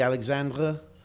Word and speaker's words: Alexandre: Justin Alexander. – Yep. – Or Alexandre. – Alexandre. – I'm pretty sure it's the French pronunciation, so it'd be Alexandre.